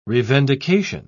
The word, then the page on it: revendication: British English